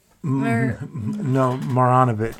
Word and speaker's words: Marjanovic: Marjanovic.